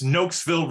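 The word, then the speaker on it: Nokesville: It's Noakesville, Virginia. Noakesville, Virginia.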